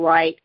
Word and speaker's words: Wright: I can be found on social media, primarily Facebook under Rachel Wright. It's spelled like Rachel, R-A-C-H-E-L, last name Wright, W-R-I-G-H-T.